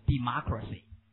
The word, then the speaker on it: democracy: 刚才民主怎么读 democratic 那么这个怎么读 No, 错了念 democracy democracy、啊、这个重音变化这个如果、